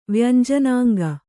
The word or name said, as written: ವ್ಯಂಜನಾಂಗ